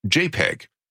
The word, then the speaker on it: JPEG: For example, a graphics program called Joint Photograph Experts Group was shortened to .jpg and pronounced JPEG.